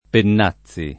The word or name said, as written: Pennazzi